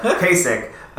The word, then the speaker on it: Kasich: and I always get his name wrong, it's Kasich, whatever, the Ohio Kasich. – John. Kasich, there you go, Kasich.